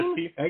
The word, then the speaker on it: Lipke: We're talking about Lyman Limpke, I believe that's how you say his name, and Cameron Again. – Yeah, Limpke.